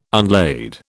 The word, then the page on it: unlade: British English